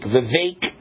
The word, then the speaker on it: Vivek: Vivek, what is it? It rhymes with cake. Vivek, hard to pronounce.